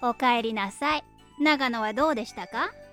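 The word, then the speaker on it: اوکایری ناسای (おかえりなさい): اوکایری ناسای. – اوکایری ناسای. – اوکایری ناسای.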